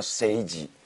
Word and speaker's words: 政治: 正好在日语里面，性是与政治是发音一样，叫 seiji。 – seiji 下半身的 seiji 和上半身的 seiji。